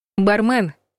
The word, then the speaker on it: бармен: По аналогии с бизнесменом или суперменом, ударение в слове «бармен» хочется поставить на последний слог. Тут-то и подстерегает ловушка. Большинство словарей фиксирует вариант «бармен».